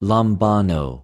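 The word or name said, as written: λαμβανω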